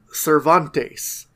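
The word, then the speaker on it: Servantes: The butler for Don Rodrigo, his name is Cervantes, but it's spelled like servant, but it's pronounced like Miguel Cervantes, the great Spanish writer.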